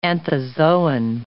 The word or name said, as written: anthozoan